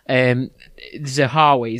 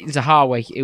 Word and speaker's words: Zahawi: Zahawi, is that how you say his name? – I think so, yeah. – Zahawi,